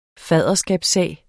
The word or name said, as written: faderskabssag